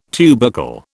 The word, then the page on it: tubercle: British English